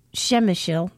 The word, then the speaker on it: Przemyśl: Disembarked in Krakow, repeated the read the air move, and because I find the town named Przemyśl rather difficult to pronounce, it's Przemyśl, so you can see how well I'm pronouncing it for you here now.